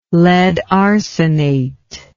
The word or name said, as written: lead arsenate